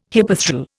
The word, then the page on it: hypethral: Indian